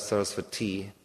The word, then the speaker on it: Saraswati: So the Prakashana Saraswati, actually it's a long I on the end, it's goddess Saraswati.